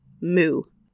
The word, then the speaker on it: mo'o: Now, I want to let you know, before I say this, this is spelled moo. And so I kept looking up the moo spirit, and it was really funny to me. But it's actually the mo'o Hawaiian water spirit.